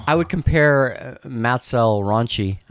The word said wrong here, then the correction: El Rancho: I mean, El Ranch. Now I can't say it right. Matt's El Rancho. – Rancho.